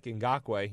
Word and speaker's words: Ngakoue: Leaders in the sack department, Josh Allen and Yannick Ngakoue.